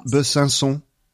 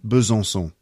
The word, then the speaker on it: Besançon: Besançon. – Besançon? – Not bad. Besançon. – Besançon. – That was really good. Besançon. That second time.